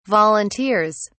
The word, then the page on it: volunteers: British English